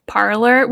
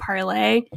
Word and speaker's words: Parler: Parler, which I did not realize that people were pronouncing it Parler and not Parlay, which is like a bastardization of the French to begin with.